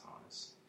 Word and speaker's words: Honus: It's pronounced Honus.